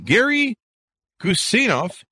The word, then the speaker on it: Gary Guseinov: Gary Guseinov is on the show with us today.